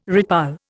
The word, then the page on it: redpoll: Indian